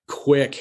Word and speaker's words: Quiq: To our audience, Quick is spelled Q-U-I-Q, right? – Yes.